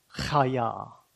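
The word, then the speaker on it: Chaya (חַיָּה): And then the second word, let me explain how to pronounce it. Chaya. So that, the dot under it, it's a very guttural sound. You're wanting to spit on the back of the head of the person in front of you.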